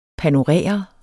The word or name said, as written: panorere